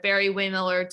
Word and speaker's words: Barry-Wehmiller: So the last year you were a validation engineer at Barry-Wehmiller Design Group.